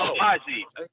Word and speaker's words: Agbaji: And then at 13, you got Ochai Ajabi going to the Charlotte Hornets. So Agbaji. You don't pronounce the G.